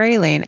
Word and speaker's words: Skrayling: Skraylene. S-K-R-A-Y-L-I-N-G.